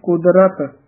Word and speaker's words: कुदरत: इसी तरह ही कुदरत रारा पूरा बोलना है कई बार कुदरत द्रत बोलिया जाता है कुदरत रारा पूरा उच्चारण शुद्ध करना है कुदरत कावन कहा विचार।